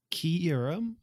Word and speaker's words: Kyurem: Apparently we pronounce Kyurem wrong. It's not Kyurem, it's Kyurem. Kyurem? – Ah, Kyurem. Oh, okay. Yeah, like key, like K E Y.